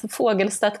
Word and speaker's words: Fagelstedt: So, yeah, so in Swedish, it's Fagelstedt Communication.